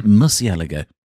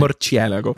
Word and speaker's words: Murciélago: It's the Murciélago. – Yeah, that's how you say it. – Murciélago.